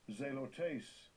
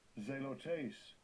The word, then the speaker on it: Zelotes: Zelotes, let's hear the pronunciation. And we're winding down this session. – Strong's G2208. Zelotes. Zelotes.